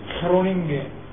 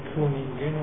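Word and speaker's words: Groningen: هم پیش مرام این درست خرونینگه که به اشتباه بعضی مثلا کرونینگن و یا مثل چرونینگن هرچی میشده، ولی به هلندی این خیلی تلفظ میشه.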